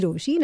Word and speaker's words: Rochie: मेरी नाम है मिर्ची रोशी.